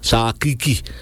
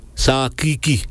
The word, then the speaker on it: Sarakreek: En hij zei, je moet alvast teruggaan, zoals het wordt uitgesproken in de Maron-talen, Sakiki. Ja, let je goed op. Sakiki. Die R wordt niet uitgesproken. Sakiki.